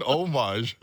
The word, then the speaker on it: homage: It was homage? homage. – Is that how you pronounce it? – It's not. No, it's, you called it a homage. – Wait, I called it a fromage. – It's an homage.